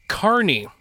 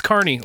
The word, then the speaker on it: Kearney: and apparently it's pronounced Carney. – And, well, that's even better. – Yeah. – So yeah. uh, I just told him that I would try to flip you as much shit as possible. It's Carney, like a uh, carniv- carnival folk, not Kearney. Like, even though it's spelled like Kearney, apparently if you go on the Wikipedia page, it'll tell you exactly how to pronounce it.